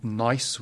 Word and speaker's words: gneiss: We call them metamorphic rocks, and they have lovely names like schist, say it carefully, and gneiss with a silent G.